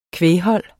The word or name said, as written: kvæghold